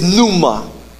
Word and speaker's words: pneuma: The Spirit, the word Spirit here in the Greek is pneuma.